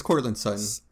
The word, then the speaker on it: Courtland Sutton: I know I'm gonna mispronounce his name. – It's uh, it's Courtland Sutton.